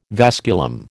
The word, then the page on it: vasculum: American English